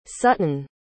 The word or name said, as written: suttin